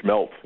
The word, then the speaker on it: smelt: You talk about schmelt. You know, you sort of the Germanic pronunciation.